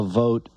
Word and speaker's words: Vought: Yeah, in reality, it wasn't about, slowing down the vote for Russell. Vote. There we go. We got the correct pronunciation of his name there.